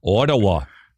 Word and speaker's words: Ottawa: How did you say that? – Ottawa? Ottawa? How am I supposed to say it? – Ottawa. – Oh, okay. – Like that.